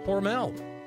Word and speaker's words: Hormel: introduced by Hormel, or Hormel, as the locals say.